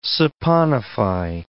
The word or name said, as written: saponify